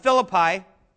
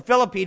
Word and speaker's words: Philippi: So you think of this big city of Philippi, or Philippi, depending on how you say it, if you're Canadian or not.